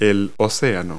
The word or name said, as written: ocean